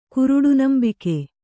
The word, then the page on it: ಕುರುಡುನಂಬುಗೆ: kuruḍu nambuge